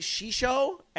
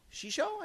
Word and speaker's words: Xixo: Well, the two-man team behind this game, am I saying this right? Shisho, X-I-X-O? Shisho, I guess would be the way to say that.